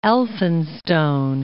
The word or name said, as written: Elphinstone